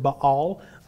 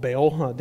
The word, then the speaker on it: Baal: Or more properly pronounced Baal, but we call it Baal down here in America in the South.